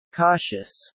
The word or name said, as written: cautious